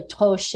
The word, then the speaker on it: troche: Or you can take it as a troche, or it's really toche in French, but we Americans butcher the pronunciation.